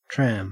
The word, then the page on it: tram: Australian English